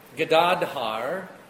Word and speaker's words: Gadadhar: Gadadhar. The final A is silent.